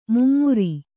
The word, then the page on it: ಮೈಮರೆ: mai mare